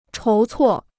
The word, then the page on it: 筹措: chóu cuò